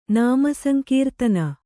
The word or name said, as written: ನಾಮಸಂಕೀರ್ತನ